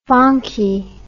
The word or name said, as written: fonky